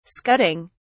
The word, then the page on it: scudding: British English